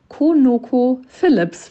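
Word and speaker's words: ConocoPhillips: Ähm, das Einzige, was mir aufgefallen ist, ich habe äh, den Firmennamen leider ungefähr 5000 Mal falsch gesagt. Vielleicht kannst du meine Nachricht am Anfang der Folge einmal einspielen. Das heißt Konoko Philips für alle, die es jetzt raushören aus der gesamten Folge.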